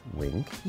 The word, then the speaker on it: Winc: And so that's why we're so excited to bring you guys Wink, which is spelled W-I-N-C. And Wink is a service that lets you easily discover great wines that match your taste.